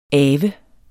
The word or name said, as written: avet